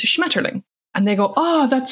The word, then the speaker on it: Schmetterling: In Germany, it's Schmetterling, which is not an ugly word inherently. It's just your bias against the German language makes you pronounce it in an ugly way.